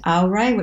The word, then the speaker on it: Aurai: Another one that we've got is, it's pronounced Aurai, which is Latin, but it's actually spelt A-U-R-A-I.